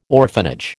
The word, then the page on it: orphanage: American English